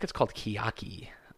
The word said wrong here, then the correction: taiyaki: I want to say Miyaki? Kiaki. Miyaki. No? Taiki. Sorry. Oh, God, I butchered that. Taiki. T-A-I-Y-A-K-I.